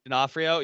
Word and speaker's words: D'Onofrio: Vincent D'Onofrio. – Vincent D'Onofrio. – D'Onofrio.